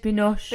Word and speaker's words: Binoche: So the first lovely lady that gets mentioned is Juliette Binoche. – Juliette Binoche. – Binocchi. We're going to have some fun with my pronunciations of names today. Everyone's really aware that I am not good at this. – Binoche.